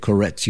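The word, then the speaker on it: Korets: He was kind of a cultural leader of Koretz, if that's how you say it, Koretz, Ukraine.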